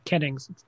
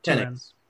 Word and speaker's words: Kennings: The Keenings, am I saying that right? Is it two E's? I don't remember. – Kennings. – Kennings. Two N's. Close.